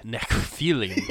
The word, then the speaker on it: Nephilim: No, it's actually Nephilim.